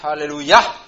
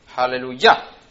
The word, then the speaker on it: aleluya: Aleluya, en hebreo es aleluya, con el acento en la A final, aleluya.